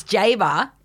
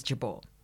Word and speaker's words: Jabour: But the original pronunciation, the Lebanese pronunciation, and the one that I use now is Jabour. – Okay. – But I respond to Bridie Jabour all the time when I'm back home.